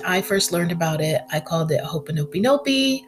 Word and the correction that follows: Ho'oponopono: Ho'oponiponi. It was hard. I had to YouTube the pronunciation of this word, but I finally got it together, y'all. It's Ho'oponopono.